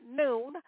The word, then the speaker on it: Nune: Listen, it go by Noon, N-U-N-E, pronounced Noon, also known as Mr. Propane.